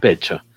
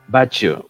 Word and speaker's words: Bacho: Eh, beso para el Comic Reporter, que también creo que a los poblanos, como, como el señor Genaro Vega, le gustan los becho. Bacho. Bacho se pronuncia un bacho.